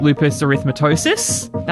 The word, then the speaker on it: lupus erythematosus: She then was diagnosed with functional neurological disorder and systemic lupus erythematosus.